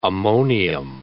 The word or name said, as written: ammonium